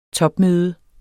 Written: topmøde

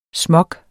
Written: smog

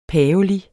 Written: PAVELIG

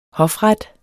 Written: hofret

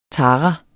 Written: TARAEN

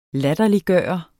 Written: LATTERLIGGJORDE

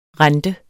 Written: rente

